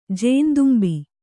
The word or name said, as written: ಜೇನ್ದುಂಬಿ